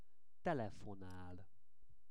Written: telefonal

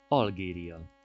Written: Algeria

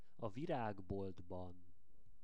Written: a viragboltban

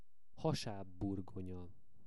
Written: hasabburgonya

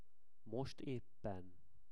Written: most eppen

